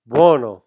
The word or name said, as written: buono